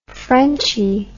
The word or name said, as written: frenchie